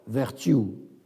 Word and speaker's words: Vertue: George Vertue, ça s'écrit comme vertu avec un E, V-E-R-T-U-E, né en 1684, mort en 1756.